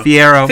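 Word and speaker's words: Fiyero: Fiero.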